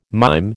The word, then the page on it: mime: American English